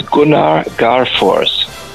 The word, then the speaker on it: Gunnar Garfors: His name is Gunnar Garfors. – Perfect. How do you spell that? – Gunnar, that's like a gun, G-U-N-N-A-R, and Garfors is G-A-R-F-O-R-S.